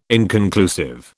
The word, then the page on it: inconclusive: British English